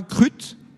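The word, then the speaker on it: Kruth: Vous habitez, alors, est-ce que je prononce bien, Crut? – Tout à fait, c'est ça.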